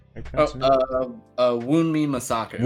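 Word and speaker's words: Wunmi Mosaku: I forgot where I saw her initially, but Wumba, wait, that's how you pronounce her name again? – Wunmi Masaka. – Wunmi, my bad.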